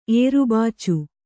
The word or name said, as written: ಏರುಬಾಚು